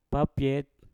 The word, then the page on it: papyet: Mpur